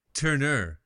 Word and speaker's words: Tourneur: Tourneur.